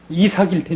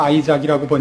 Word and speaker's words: 이삭: 이삭일 테니까. 이삭이니까 아이작이라고 번역이 됐을 거예요. 영문학을 하면서 개뿔도 성경을 모르는 사람이 번역을 했으니 아이작이라고 그랬죠. 이삭 아니에요. 이삭. 뭐 할 수 없죠. 또 영어식 발음으로 했을 테니까.